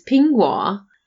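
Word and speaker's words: pingguo (苹果): So the word apple is pingguo, but in Shanghai it's pronounced bingguo, which means pass away from illness.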